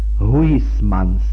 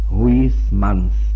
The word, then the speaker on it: Huysmans: Já si vzpomínám na to, co vypráví francouzský spisovatel, ho vyslovím tak, jak se píše, Huysmans, tvrdé I, Huysmans.